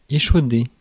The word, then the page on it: échauder: /e.ʃo.de/